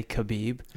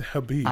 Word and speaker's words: Khabib: First off, I hate that you say Habib and I say Khabib. – It's Habib. – I know, I just pronounced the K.